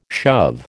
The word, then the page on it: shove: American English